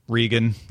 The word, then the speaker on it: Reagan: Interestingly, number 100, and this is not an old lady name, is Reagan. But maybe they say Regan. – Regan, yeah. – But it's spelled like the president.